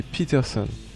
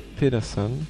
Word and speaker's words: Peterson: Donc j'avais écorché un peu le nom et c'est Peterson et pas Pedersen, comme je le prononçais mal.